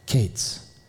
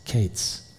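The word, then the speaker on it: kates (קץ): It sounds like the word end, kates. Kayitz, kates. The word fruit, kayitz, the word end, kates.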